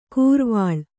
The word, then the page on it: ಕೂರ್ವಾಳ್: kūrvāḷ